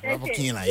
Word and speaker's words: Rybakina: Rybakina, yes.